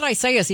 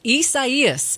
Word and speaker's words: Isaias: I said it wrong earlier. It's not Isaias, even though it looks like it. It's Isaias, is headed toward the Gulf Coast.